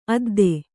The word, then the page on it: ಅದ್ದ: adda